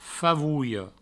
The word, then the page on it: favolha: favouio; Provençal